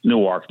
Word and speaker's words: Newark: We pronounce it Newark, not Newark, Delaware.